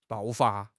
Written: 爆发

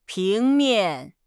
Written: 平面

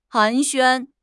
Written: 寒暄

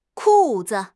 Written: ku4 zi5

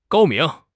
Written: 高明

